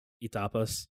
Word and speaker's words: etapa: So it's like etapas. Did I say it right? Etapas? – Etapas? Like appetizers? – No, E-T-A-P-A.